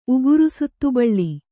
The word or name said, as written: ಉಗುರುಸುತ್ತುಬಳ್ಳಿ